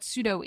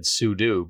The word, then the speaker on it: sudo: And I've always kind of said sudo because it's super user do.